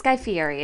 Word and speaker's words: Fieri: It's Guy Fieri is how he says it.